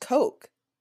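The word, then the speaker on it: Koch: I think they are the Coke brothers, but I think I missaid it because of how my stepmother's family pronounces it.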